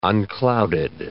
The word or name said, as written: unclouded